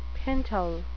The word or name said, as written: pintle